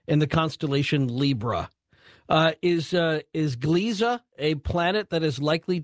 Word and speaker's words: Gliese: Gliese? – Gliese, yeah.